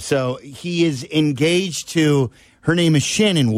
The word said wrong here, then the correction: Cheyenne: That's how you pronounce it, is Cheyenne. It is not Shannon Woodley. – I don't think there's enough N's for it to be Shannon.